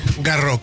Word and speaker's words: Garros: Garros, creo que es Garros. – Sí, por la S, el final en francés. – La S no se pronuncia.